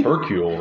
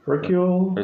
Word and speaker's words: Hercule: Hercule, right? – Hercule. It's kind of like Hercules, but without the S.